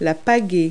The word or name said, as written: paddle